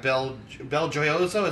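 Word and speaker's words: BelGioioso: Bel Gioioso, is that how you say it? – Yeah.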